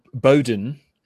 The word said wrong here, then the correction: Boudin: How do you say his name? Chesser Boudin. Ah. Yes.